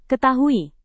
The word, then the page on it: ketahui: indonesian